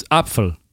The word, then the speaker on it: Apfel: And in German it's Apfel.